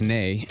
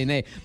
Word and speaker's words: Nez: Yeah, except that's not how you say it in French. It's nay. It's N-E-Z, but you say nay.